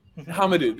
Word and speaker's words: Hamidou: Hamidou, thank you.